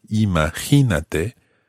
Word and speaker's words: imagínate: It carries an accent on the middle I, imagínate, so that the stress falls in the same part of the word as the normal verb, imagina.